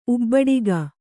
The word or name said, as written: ಉಬ್ಬಡಿಗ